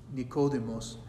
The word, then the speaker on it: Nicodemus: Words in Greek are accented, and the name Nicodemus has his accent on the second syllable.